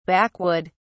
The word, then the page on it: backwood: British English